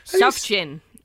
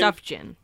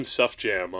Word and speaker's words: Sufjan: Sufjan. – Is that how you say his name? – Sufjan. – Okay, because I called him Sufjam on Twitter, and nobody got really angry at me yet.